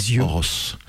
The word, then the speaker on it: Ojos: Ça s'écrit O-J-O-S et ça se prononce Horos.